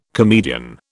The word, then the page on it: comedian: British English